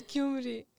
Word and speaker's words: Gyumri: Gyumri City Stadium is the oldest stadium Gyumri. – In Armenia. How did I say it? – Gyumri. It's Gyumri.